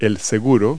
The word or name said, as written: door lock